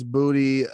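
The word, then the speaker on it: Boutte: Butte, B-O-U-T-T-E. I don't know how to say it. Booty, yeah. Louisiana, Hanville High School. – Well, yeah, it's Booty, Louisiana, because that place sucks ass.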